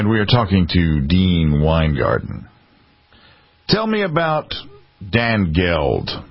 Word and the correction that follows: Danegeld: Well, I pronounce it Dane Geld. – Dane Geld, okay. – Because it's spelled that way.